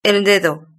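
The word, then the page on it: el dedo: European Spanish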